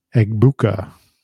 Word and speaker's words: Egbuka: Is it Egba? – Mbuka. – Egbuka.